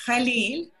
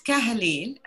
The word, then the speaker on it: Khalil: And it's Khalil, not Kahlil, and I'll tell you why.